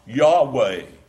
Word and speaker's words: Yahweh: And 6,700 of the 8,000 usages are a translation of the Hebrew which we pronounce Yahweh.